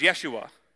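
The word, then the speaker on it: Yeshua (ישוע): Jesus, we say Jesus in English, but in the Hebrew, is actually pronounced Yeshua, which literally means God saves.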